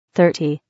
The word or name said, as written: 30